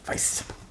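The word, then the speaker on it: Vice: Weiß. Muss man so aussprechen.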